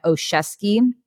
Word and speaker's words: Oshesky: I go by Haley Rianne on social media, because my name fully, in case anyone's wondering how to pronounce my whole name, it's Haley Rianne Oshesky. It's my married last name. So I don't go by Oshesky because it's just way too long.